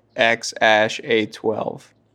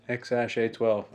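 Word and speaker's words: X Æ A-12: I think you just pronounce that A-E thing ash for some dumb reason. So it's like X-A-12 is his son's name. – X-A-12.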